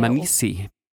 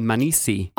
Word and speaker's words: Manisi: Demansi, which is where... – Manisi. – How do you pronounce it? – Manisi. – Oh, you don't say the D? – The D is barely pronounced.